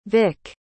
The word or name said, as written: vick